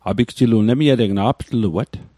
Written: aptluat

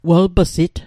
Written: welp'sit

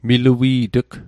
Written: milui't'g

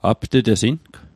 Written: apt'tesing

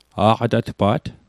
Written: aqatatpa't

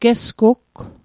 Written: gesgugg

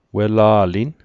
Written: wela'lin

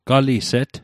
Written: galiset

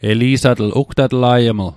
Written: eli'satl

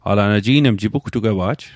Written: jipugtugwewa'j

